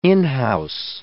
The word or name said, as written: in-house